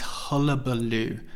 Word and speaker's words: hullabaloo: Let's look at the pronunciation. Hulla, hulla, ba, ba, loo, loo, hullabaloo.